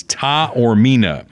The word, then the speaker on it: Taormina: I actually asked him how to pronounce that, and he phonetically spelled it for me because a bunch of friends have gone to this doctor, and they all gave me a different pronunciation. Mm. And I know his wife is a judge in Blue Springs, and we botched her name when we brought her onto the court. So he gave me a pronunciation guide. It is Taormina.